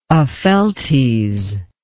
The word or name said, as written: Opheltes